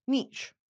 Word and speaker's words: niche: Niche rhymes with sheesh, and niche rhymes with pitch.